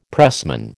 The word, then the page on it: pressman: American English